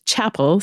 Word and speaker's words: Chappell: So so my first name Deanna is spelled D E E A N N A, and my maiden name is Chappell C H A P P E L L.